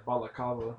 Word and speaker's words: balaclava: Baklava? I pronounce it baklava. How do you pronounce it? It's like balaclava.